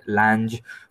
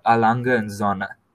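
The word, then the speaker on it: A. Lange & Söhne: I went around calling it Lange for quite a bit before I figured out that it's Alanga and Zona.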